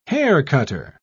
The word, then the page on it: haircutter: British English